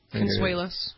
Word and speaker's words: Consuelos: Is that how you pronounce his name? Consuelos? – Consuelos, yep.